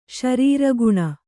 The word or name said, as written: ಶರೀರಗುಣ